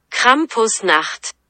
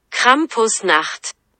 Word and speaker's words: Krampusnacht: lo googleé y busqué en alemán cómo se dice esto y se dice así. Krampusnacht. Otra vez. Krampusnacht. Así se dice.